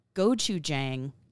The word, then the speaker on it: Gochujang: Gochujang, did I say this right? – You did.